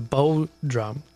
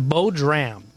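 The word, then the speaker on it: bodhrán: We had a text in from my friend Kathy who clarified that drum, the bow drum, the bow dram is how she says it's pronounced.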